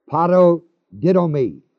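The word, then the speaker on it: paradidomi: This is the same as in verse 24 I told you, where it's the Greek word parodidomi, spelled P-A-R-A-D-I-D, long O-M-I, which literally means hand over to the power of another.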